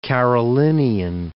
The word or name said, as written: Carolinian